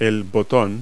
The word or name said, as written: button